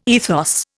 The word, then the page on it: ethos: Indian